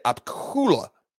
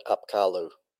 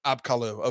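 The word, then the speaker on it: Apkallu: Yeah, and Heiser pronounces that Apkalu. – Apkalu, okay, yes.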